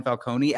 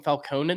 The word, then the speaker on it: Falcone: Do you think, so they bring in Maroney, the reason that they pronounced him Falcone in this has to be so that we don't confuse Maroney and Falcone, because it's pronounced Falcone in every other piece of Batman, like audio work ever. And in this, they specifically say Falcone over and over and over again.